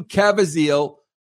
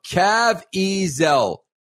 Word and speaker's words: Caviezel: Sound of Freedom sees the Passion of the Christ star, Jim Caviezel. Bro, why do I have such a hard time pronouncing that name? K-A-V-I-E-Z-E-L. Caviezel.